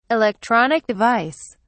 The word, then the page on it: electronic device: British English